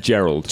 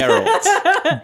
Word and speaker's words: Geralt: As the person who is the least kind of knowledgeable about the world, I've called him Geralt, Geralt, Geralt, and Gerald. – Gerard Way. – It's Geralt. – Geralt. – Geralt of Rivia.